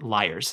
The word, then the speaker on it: lyres: Lyres, which I had to Google, is it pronounced Lears or liars. And the little lady inside the Google box said liars.